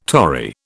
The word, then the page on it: tori: British English